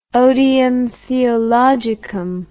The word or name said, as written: odium theologicum